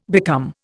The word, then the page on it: become: Indian